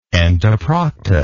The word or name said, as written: entoprocta